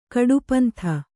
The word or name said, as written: ಕಡುಪಂಥ